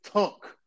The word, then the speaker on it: Tunk: So Walt pronounces it with an O. It's Tunk. T-U-N-K. Not Tunk or whatever the hell he just said.